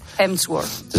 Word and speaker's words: Hemsworth: Vamos, Porque tú has visto el vídeo de, Miguel Ángel Muñoz y Crim Sherwood, ¿no? ¿Cómo se dice? – Hemsworth.